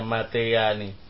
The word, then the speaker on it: متيان: Nah, نحو متى طلب كرم علمان تقول متياني في ملكة متياني tulisannya Matayani, bacanya Matayani.